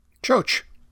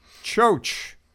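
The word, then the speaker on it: Ciocc: The bike is, and I'll spell it for you, a C-I-O-C-C. – Choach. – Very good. Choach. – With an umlaut over the I. – You are correct. It is a choach.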